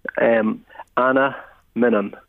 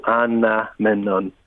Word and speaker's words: Anaminen: Break it down. 4 syllables. Anna Minnan. So, Anna Minnan. – Anna Minnan. – Easy enough. – Right. Yep. Anaminen is the way you pronounce it.